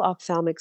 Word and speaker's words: ophthalmic: So you did pronounce it very well, ophthalmic surgery.